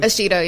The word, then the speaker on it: Ashido: Ashido, am I saying it correctly? – Ashido, yeah.